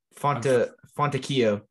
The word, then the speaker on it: Fontecchio: Is it Simi Fantecchio or how do you say it? – Fontecchio. That's how Mark Folwell pronounced it. – Was it Fontecchio or Fantecchio? – Fontecchio.